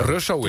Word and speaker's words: Russo: Russo en Mart Hoogkamer, zeg ik dat goed? Want ik heb altijd het idee dat de ene zegt Russo. En nee, Russo. – Russo is het.